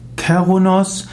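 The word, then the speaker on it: Cernunnos: Zerrunos. Zerrunos, auch ausgesprochen Kerunos, meistens geschrieben Zernunos, ist der Name eines keltischen Gottes.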